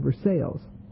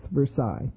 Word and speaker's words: Versailles: So I talked about the Treaty of Versailles. And Mr. Mason, who is kind of a cool guy, maybe not the world's best psychologist, said it's pronounced Versailles, which is probably not, you know, I mean, it certainly needs to be corrected.